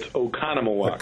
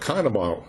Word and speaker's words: Oconomowoc: You stress the second syllable. It's Oconomowoc. – Oconomowoc.